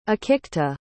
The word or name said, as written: akikta